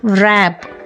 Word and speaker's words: wrap: WRAP, ರ್ಯಾಪ್. WRECK, ವ್ರೆಕ್. ಈ ಮೂರು ಪದಗಳಲ್ಲಿ ಡಬ್ಲ್ಯೂ ಇದ್ರೂ ಸಹ ಅದು ಸೈಲೆಂಟ್ ಆಗಿದೆ. ವ್ರ್ಯಾತ್, ವ್ರ್ಯಾಪ್, ವ್ರೆಕ್ ಅಲ್ವಾ.